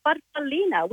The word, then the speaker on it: farfallina: Well, growing up, as we're little girls, Mum called it a farfallina, which in Italian means a little butterfly.